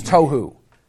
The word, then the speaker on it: tohu: Strong's Concordance defines tohu. T-O-H-U, if you want to spell it in English. T-O-H-U. Tohu.